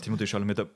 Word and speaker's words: Timothée Chalamet: Timothée Chalamet, oder wie spricht man den aus? – Timothée Chalamet, also Mr. Wonka.